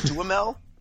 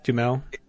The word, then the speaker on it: Duhamel: Duhamel? – Well, in French, it'd be Duhamel. Like, if you were actually going to pronounce it the French way, Duhamel.